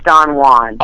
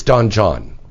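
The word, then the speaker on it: Don Jon: And now, is it Don Juan or Don John? I think it's Don Juan. – Oh. – I think the J is silent or something. – I think it's Don John. – Okay, I'm wrong.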